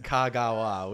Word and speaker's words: Kagawa: Just for the record, Kagawa.